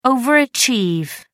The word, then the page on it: overachieve: American English